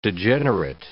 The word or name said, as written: degenerate